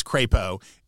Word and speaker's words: Crapo: named Mike Crapo, which might be mispronounced sometimes, and they forgot to put a pronouncer for Bush to make the which should have been in the document like absolutely whatever you do, it's Crapo, and Bush of course said, oh, I want to thank Senator Crapo for," and it was like, oh.